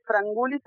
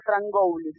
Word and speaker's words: Frangoulis: ¿Estoy diciendo bien tu apellido, Carolina? – Sí, mi apellido es Frangoulis, se escribe Frangoulis. – Claro, por eso uno tiende a pasar la O a U. – Está perfecto.